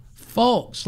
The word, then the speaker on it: Fulks: Paige, how would you pronounce it? F-U-L-K-S. Folks, like Incredible Hulk.